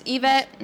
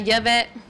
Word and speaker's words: Yvette: It's Yvette, not Yvette, with a Y, not an E.